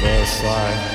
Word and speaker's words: Versailles: We brought the loud, you got the silent L like Versailles.